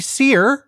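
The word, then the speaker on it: SERE: I graduated the U.S. Army SEER, pronounced like the word seer, that's S-E-R-E, school, and all I got was this lousy username.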